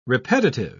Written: repetitive

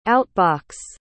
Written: out-box

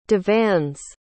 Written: divan's

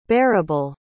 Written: barabal

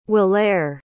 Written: hweolere

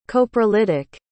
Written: coprolitic

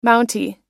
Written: mountie

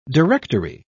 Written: directory

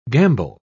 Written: gambol

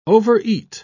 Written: overeat

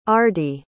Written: ardi

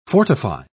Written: fortify

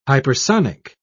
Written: hypersonic

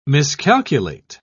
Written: miscalculate

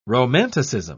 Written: romanticism

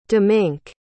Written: dominque

